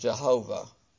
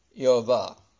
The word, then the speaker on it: Yehovah: Actually, it's the way we pronounce it is Jehovah. In reality, the Hebrew, RJ is pronounced, you know, when it has a J, is pronounced with a Y, as a Y. Yehovah is the Hebrew for the Lord God.